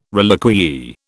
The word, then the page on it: reliquiae: British English